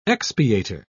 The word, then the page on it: expiator: British English